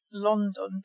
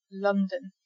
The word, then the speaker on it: London: So the first O in London is like the U in sunshine or summer. It's London, so you don't pronounce that first O like an O, sounds like an orange.